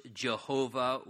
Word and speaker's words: Jehovah: Verse 1 opens by telling us, notice, the Lord said to my Lord, and notice the distinction there, capital L-O-R-D, that's that Hebrew tetragrammaton, the Y-H-V-H, we believe it's pronounced Jehovah, or some believe Yahweh. We can't be 100% certain.